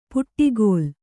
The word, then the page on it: ಪುಟ್ಟಿಗೋಲ್: puṭṭigōl